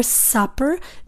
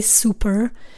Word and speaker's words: supper: Supper means dinner. Be careful with the pronunciation of that word. You don't want to say super or something like that, right? You want to say supper, okay?